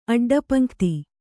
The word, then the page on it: ಅಡ್ಡಪಂಕ್ತಿ: aḍḍapaṇkti